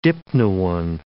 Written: dipnoan

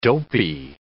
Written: dopey

